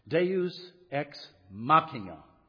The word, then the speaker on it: Deus ex machina: He is absolutely about to die, and then suddenly, and I'll try to pronounce this as I think it's supposed to be pronounced, Deus ex machina.